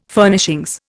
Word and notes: Furnishings: Indian

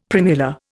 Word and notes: primula: Indian

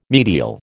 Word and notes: medial: American English

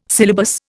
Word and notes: syllabus: Indian

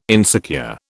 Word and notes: insecure: British English

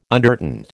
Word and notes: undertint: American English

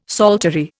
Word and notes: psaltery: Indian

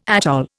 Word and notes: atoll: Indian

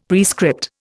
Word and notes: prescript: Indian

